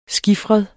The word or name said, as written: skifret